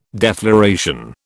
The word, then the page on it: defloration: British English